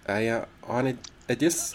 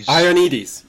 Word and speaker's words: Ioannidis: You quote a guy, Ioannidis, I don't know how to pronounce his name, Ioannidis, Ioannidis. – Ioannidis, yes.